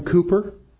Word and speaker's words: Cowper: Have you heard of the poet, songwriter, William Cooper? And it's interesting because his last name is spelled C-O-W-P-E-R. It doesn't look like Cooper, but it's William Cooper.